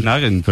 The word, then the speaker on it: Nugent: Yo era de Ted Nugent. – Pero la pronunciación correcta es Nugent.